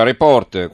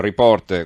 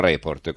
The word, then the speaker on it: Report: Continuano le polemiche sulla trasmissione televisiva Report, qualcuno mi scrive perché io la chiamo Report con l'accento sulla O quando tutti dicono Report con l'accento sulla E, eh, la domanda è di Pierluigi da Roma. la risposta è semplice perché si dice report anzi si dovrebbe dire report,